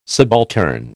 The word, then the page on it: subaltern: American English